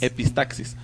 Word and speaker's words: epistaxis: No queremos que les dé una epístasis. – Epístasis. Ay, desde aquí en médico. – Bueno, pero por supuesto. Epitaxis se pronuncia. – Bueno, yo confío en ti. Como tú digas, así es. – Epistaxis.